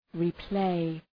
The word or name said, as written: replay